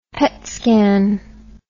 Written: PET scan

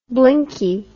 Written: blinkie

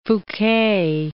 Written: Foucquet

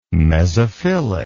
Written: mesophilic

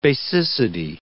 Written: basicity

